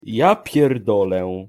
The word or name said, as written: ja pierdole